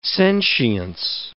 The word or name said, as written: sentience